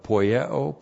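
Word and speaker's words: Poieo (ποιέω): This is the perfect active indicative of poieo plus the accusative singular of autos plus the accusative singular of pselstes. Now we have the verb poieo. P-O-I-E long O. Poieo.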